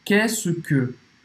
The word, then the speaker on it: Qu'est-ce que: Qu'est-ce que? Qu'est-ce que? – Qu'est-ce que? Presta atenção. Qu'est-ce que? Olha, é tanta, tanta letra, tanta coisa, tem traço, tem, tem tudo para você dizer essa pronúncia tão simples.